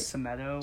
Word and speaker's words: Semedo: Semedo? I think I got that one right. – Semedo.